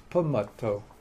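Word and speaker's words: pamatta: And then a negligent person would be pamato, P-A-M-A-T-T.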